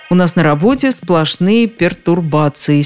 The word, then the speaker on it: пертурбация: Ведь в этом слове нет никаких пере, нет никаких труб. Иными словами, не стоит говорить перетурбация и перетрубация. В общем, раз уж мы так любим иностранные слова, давайте научимся их писать и произносить правильно. Пертурбация.